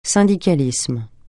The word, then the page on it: syndicalisme: francais